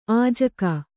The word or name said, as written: ಅಜ್ಜುಗ